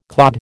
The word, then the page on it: clod: American English